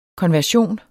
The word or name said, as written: konversion